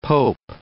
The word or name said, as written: pope